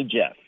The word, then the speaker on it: Jeff: Is that Jeff with a J or a G? – It's with a J. – Right. It's not Geoff. – No, I don't like Jeff. That. People always pronounce it. Yeah, that's weird. Geoff, I don't get it. No, it's Jeff. Just like a manly Jeff. Yes.